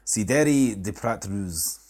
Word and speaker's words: Cidre de Prat Rouge: We have a French one here, which says, I'll try and pronounce it, Cidre de Prat Rouge. Is that right? – Will I retranslate for our French listeners? – Please try. – Cidre de Prat Rouge. – Aye, see, close enough.